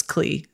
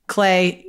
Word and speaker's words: Klei: Also, it is spelled K-L-E-I. I definitely thought it was Klee. So Clay, you get it. I get it now.